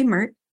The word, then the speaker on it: MeRT: And am I saying that correctly, MERT? – You're saying that absolutely correctly.